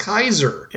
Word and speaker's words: Caesar: Caesar is pro- actually pronounced Kaiser.